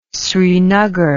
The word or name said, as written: srinagar